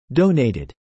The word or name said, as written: donated